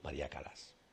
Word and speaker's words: Callas: Que hoy nos trae, bueno, se escribe María Callas, pero normalmente decimos María Calas, ¿eh? Si alguien lo dice Callas, no se ha equivocado.